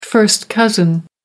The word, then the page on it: first cousin: American English